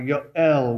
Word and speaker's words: Yoel: It is a compound word, really, made up from two of the names of God, Jehovah and Elohim, and therefore you have his name, Joel, or Yoel, which is how we pronounce Joel in our own Bible.